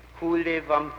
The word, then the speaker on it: Kuhle Wampe: The name is the name of a suburb of Berlin, Hule Wampe. – Can you spell that, please? – K-U-H-L-E-W-A-M-P-E.